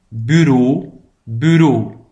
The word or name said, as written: het bureau